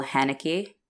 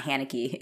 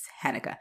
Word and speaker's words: Haneke: It was directed by Michael Haneke. That's how you pronounce it. I actually looked it up because I was like, this, it's not Haneke, it's Haneke.